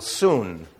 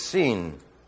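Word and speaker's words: seen (σύν): Unfortunately, pronounced in Bible school, "soon." It's not "soon," it's "seen." It's the equivalent of this.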